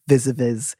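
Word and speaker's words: vis-à-vis: which seems like a good place to be vis-a-vis Steve Allen. Via-vis Steve Allen? Vis-a-vis. – Yeah, sure. – How do you say the phrase via-vis? Vis-a-vis? – I say vis-a-vis, I think.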